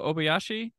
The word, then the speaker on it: Obayashi: So, is it Nobuhiko Obayashi? Is that the director? Did I murder it? – You got it. That's good. – All right. – You're on the right track there. – White United States guy pronounced it. It's close to correct.